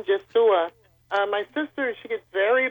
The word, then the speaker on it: Yeshua: The proper pronunciation of the Lord's name as he would have been known in Hebrew is Yeshua. Yeshua.